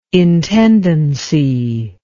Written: intendancy

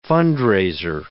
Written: fund-raiser

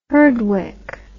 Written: herdwick

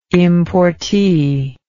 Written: importee

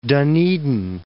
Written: Dunedin

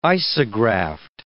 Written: isograft

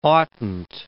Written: oughtn't

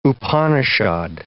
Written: Upanishad

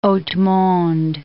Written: haute monde

